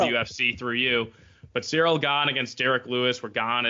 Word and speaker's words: Ciryl: Because that is the main event this week between Derek Lewis and Ciryl Gane, mm-hmm. if I'm pronouncing that right. – Ciryl, close enough.